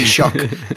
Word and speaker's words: Taoiseach: You have Leo Varadkar, who's the you have to pronounce that for me. – I'll Taoiseach. Help you. Taoiseach, the Taoiseach or the prime minister.